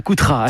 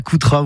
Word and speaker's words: Coutras: Vous l'avez partiellement évoqué depuis ces ruches en Val de l'Isle, à Coutras. À tout de suite. – C'est bien prononcé, bravo, bravo. C'est Chemin de l'Ovira et c'est à Coutras où vous retrouvez Mylène Loubet et toute sa famille et évidemment ses abeilles et ses miels absolument délicieux.